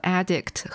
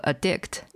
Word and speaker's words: addict: 而且它在读音上就是根据重音去区分它的动词和名词形式，就像那个 addict 和 addict 一样。